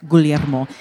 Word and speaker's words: Guglielmo: But you are, being that you are Italian, yeah, the second G is silent, which is Guglielmo, and it really means Williams.